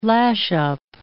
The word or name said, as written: lash-up